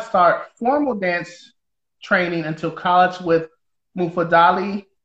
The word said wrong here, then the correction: Mufundala: Did I say that right? – Mufundala. – Mufundala. See? And I'll talk about that later.